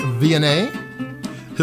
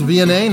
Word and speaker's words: Viognier: Today's wine is a 2012 Fortat Viognier, Hills Reserve Viognier from the Languedoc district of southwest France. That is Viognier, not Viognier, in case you haven't heard the grape pronounced before.